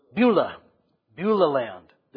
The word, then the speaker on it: Beulah Land: This comes right before Beulah Land. How do you pronounce that? Beulah Land.